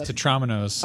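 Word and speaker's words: tetrominos: Am I saying tetraminos right? I don't know. Who knows? But I'm controlling the show. – Pronounced tetraminos.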